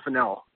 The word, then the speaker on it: Fennell: No, it's actually pronounced Fennell. – Fennell. All right. So it's not like the spice that I put in my pasta.